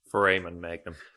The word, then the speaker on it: foramen magnum: The strongest indicator of whether these species were bipedal or not is the placement of the, correct me if I'm wrong in the pronunciation here, the foramen magnum. – Foramen magnum.